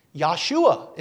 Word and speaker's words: Yahshua: Joshua is the name of Jesus in Hebrew. Yahshua is really how it would be pronounced.